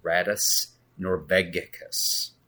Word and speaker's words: Rattus Norvegicus: Hey gang, I just wanted to drop a quick note here to let you know that I butchered the name of this album when I first recorded this podcast. The correct pronunciation is Radius Norvegicus.